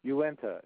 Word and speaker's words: Uinta: Uinta. It's a, How do you spell that? – It's U-I-N-T-A.